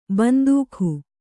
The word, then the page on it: ಬಂದೂಕು: Kannada